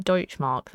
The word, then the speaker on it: Deutschmark: Is that how you pronounce it? The Dutch Mark, the official currency of Western Germany. I feel like I've not said that right. – It'd be the... Is it got a D-E? – Is it like Deutschmark then? – Yeah. – Yeah, that's fine. Yeah, Deutschmark. So, Honestly.